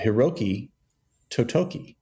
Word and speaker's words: Hiroki Totoki: Hiroki Totoki. Is that right? – I assume that was pretty good pronunciation.